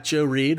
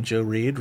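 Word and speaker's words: Joe Reid: You can find me on Twitter, railing apparently against The Social Network script, at Joe Reed. Reed is spelled R-E-I-D. I am also on Letterboxd under the name Joe Reed. Reed is also spelled R-E-I-D.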